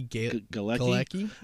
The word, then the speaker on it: Galecki: Johnny Galecki.